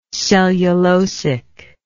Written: cellulosity

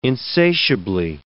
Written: insatiably

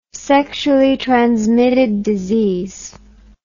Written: sexually transmitted disease

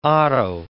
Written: otto

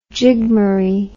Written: jigmaree

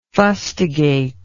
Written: fustigatory

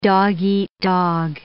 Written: dog-eat-dog

